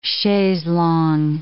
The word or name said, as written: chaises longues